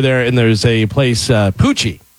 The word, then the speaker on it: Pucci: It's Poochie. And it's spelled P-U-C-C-I.